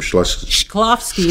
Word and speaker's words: Shklovsky: Actually, that's a translation of the original Russian book written by, how do you pronounce the name, Shklovsky. It's very hard to say. There's a K in there where it shouldn't be.